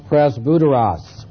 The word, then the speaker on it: presbyteros: It's pronounced presbyteros.